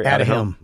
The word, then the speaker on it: Aduhelm: Aduhelm.